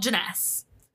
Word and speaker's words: Jness: The first one was in 2006, and it's called Jness.